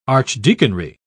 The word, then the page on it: archdeaconry: British English